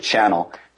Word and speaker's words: CHNL: We pronounce it channel, but I'm glad you said a CHNL so everyone knows where to find it at chnl.it.